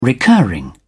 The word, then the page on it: recurring: British English